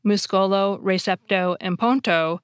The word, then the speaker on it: ponto: Receipt, which has a silent I and P in English, becomes recepto, spelled R-E-C-E-P-T-O. Bridge, which has a silent D and E in English, changes completely and becomes ponto, spelled P-O-N-T-O.